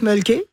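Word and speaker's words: Mölkky: On prononce mulky. Et c'est M-O-L-K-K-Y.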